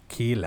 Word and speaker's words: Keeley: Keeley, that's how they say it.